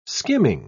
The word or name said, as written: skimming